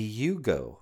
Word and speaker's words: Yugo: But, it rhymes with Geo. It's the Yugo.